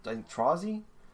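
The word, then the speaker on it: Trozei: I did buy actually Pokemon Trozzy, is how you pronounce it.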